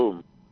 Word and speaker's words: Klum: Yeah, Klum.